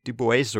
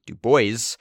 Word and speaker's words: Du Bois: And he says it is pronounced Du Bois or Du Bois.